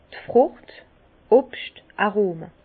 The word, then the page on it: d' Frùcht, Obscht-Arome: Bas Rhin; Herrlisheim